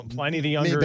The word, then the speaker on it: Pliny the Younger: So number one is Pliny the Younger. Does that sound? Now, if I mispronounce these, I don't know my beer, so. – No, Pliny the Younger is it.